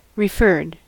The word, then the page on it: referred: American English